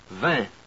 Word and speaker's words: vin: For I-N, I-M, A-I-N, A-I-M, E-I-N, E-I-M, there's another vowel. You pronounce the front vowel, eh, eh, and nasalize it. Eh, eh, eh, eh. And here are some words. Vin, pain, cinq, fin, enfin.